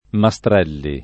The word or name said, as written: Mastrelli